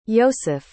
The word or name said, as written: ioseph